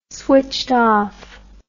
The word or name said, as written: switched off